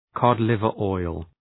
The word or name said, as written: cod liver oil